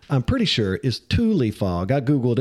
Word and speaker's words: Tule: So it's very possible that the name Jack was trying to come up with for the fog was Thule fog. T-U-L-E.